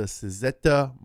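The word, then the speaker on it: Cizeta: In 1991, a handful of ex-Lamborghini Machine employees, led by Claudio Zampoli, jumped into the pool and launched the Suzetta Moroder V16T. That's how it's pronounced.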